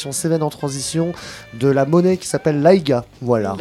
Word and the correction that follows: AIGA: Ça se dit AIGO. – Enfin, AIGO. Ça s'écrit AIGA. – Et des fois, ils disent même pas le O en fait.